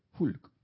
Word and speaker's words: Hulk: Hulk o Hulk. ¿Cómo se dice en inglés? Hulk. Hulk.